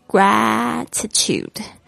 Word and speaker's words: gratitude: show gratitude，特别注意一下这个 A 这边的发音是发 A 的声音，不要念成 A。 gratitude， tude， T-U-D-E，这个声音，U 的这个声音，好好的发一下哈。